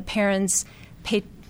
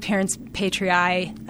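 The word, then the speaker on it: parens patriae: So they do have authority under common law, and it's mostly the parents pay, depending on how you choose to pronounce it. I pronounce it parents patriae, but it essentially means the AGs are the parent of the nation.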